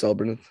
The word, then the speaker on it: Cellbrynth: Yeah, it's pronounced Cellbrynth, so yeah, you got it right.